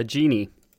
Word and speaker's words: djinn: Like in the screenshot you shared, you find a genie or a djinn, or how do you pronounce that? – A genie. – Genie. DJINNI. – Yeah.